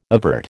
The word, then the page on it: evert: American English